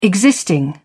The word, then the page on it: existing: British English